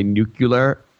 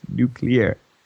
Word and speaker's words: nuclear: And I kept like, I totally had one of those moments where I was like, okay, it's kind of like when, President Bush would say nuclear, like nuclear. – Yeah. – No, it's not three syllables. It's only two. It's nuclear.